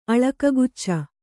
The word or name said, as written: ಅಳಕಗುಚ್ಚ